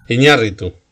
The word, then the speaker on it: Iñárritu: Y hoy estaba pensando, digo, le voy a comentar Hace tiempo vi una película que se llamaba 21 gramos de Iñaturro o algo así. lo pronuncio fatal, lo sé. – No, es tan difícil, pero bueno, se puede decir bien. ¿eh? ¿Cómo es? – Iñárritu.